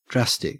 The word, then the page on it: drastic: Australian English